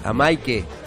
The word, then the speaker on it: Amaike: ¿Lo dije bien? Amaika. – Amaike. – Amaike, perdón, no Amaika.